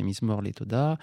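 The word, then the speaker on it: Mizmor le Todah: Alors, c'est la prononciation ashkenaz, bien sûr, c'est Mizmor les Toda, la prononciation hébraïque moderne de Jacques Fromental à Lévy,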